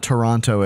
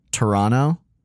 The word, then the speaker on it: Toronto: And it's not Toronto. It's Toronto. Yeah. – Toronto. I think it's like a two syllable thing.